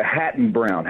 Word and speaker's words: Hatton-Brown: And did I say that right? I think I did. – No, it's Hatton Brown. Hatton Brown Publishers.